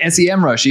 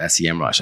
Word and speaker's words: Semrush: and I keep calling it SEMrush even though you educated me on that. – Yeah, I know, me too. I used to always call it SEMrush. I only recently discovered they actually pronounce it Semrush.